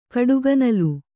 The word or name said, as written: ಕಡುಗನಲು